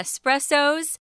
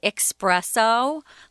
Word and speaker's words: espresso: They say espresso, like there's an X in it, like express, meaning fast. No, this is an Italian word originally, and so it's a difference. It's espresso.